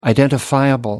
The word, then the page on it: identifiable: American English